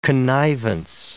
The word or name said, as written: connivance